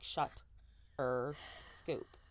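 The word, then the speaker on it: Shuterscoop: Shutter Scoop, mind you, Shutter Scoop is spelled S-H-U-T-E-R. Like, not even shudder. It's like shut-er scoop.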